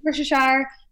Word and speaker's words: Worcestershire: Worcestershire. Yep. Yeah. Worcestershire. A lot of people say it different ways. Yeah. – I know. – I get it. Mm-hmm. I get it. – Lots of Worcestershire.